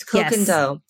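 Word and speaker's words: Koh Gen Do: Do you guys know how to pronounce that? – Is it Kogendo? – Yes, Kogendo. Yes.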